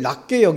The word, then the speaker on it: 낮게: 겸손한 마음으로 남을 낮게 여기고 이 발음을 잘 하셔야 됩니다. 이 낮게 남을 깔보지 말고 남을 더 올려보는 이게 인간관계의 문제를 풀 수 있는 첫 번째 비결이라는 거죠.